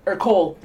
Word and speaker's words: Ercole: It's one of those ones where if you say it without the accent, it just sounds wrong. – Ercole. – Yeah. Yeah, because I assume it's like Ercole.